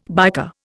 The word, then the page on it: biker: Indian